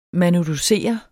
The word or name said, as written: manuducere